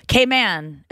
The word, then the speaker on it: Cayman: Cayman is how they say it here.